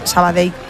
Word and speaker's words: Sabadell: Sabadell. A mí me lo decían mucho cuando estaba en Primera Federación, porque yo también decía Sabadell, que es como se dice.